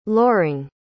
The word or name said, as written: loring